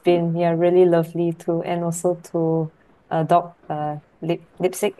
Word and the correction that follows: Leipzig: Am I pronouncing it right? – Leipzig.